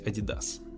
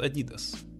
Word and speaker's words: Adidas: Или Adidas. Ну, это зависит от ваших лингвистических предпочтений. Вроде как правильно говорить Adidas. Хотя в Америке все говорят Adidas. Но это фирма европейская, если не ошибаюсь, немецкая. Так что там принят вариант Adidas.